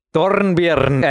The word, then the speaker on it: Dornbirn: Dornbirn, I like this pronunciation.